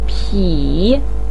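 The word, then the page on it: pi3: tone 3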